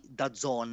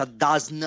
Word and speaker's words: DAZN: la pubblicità di da Zon ma perché si pronuncia da Zon? – Eh...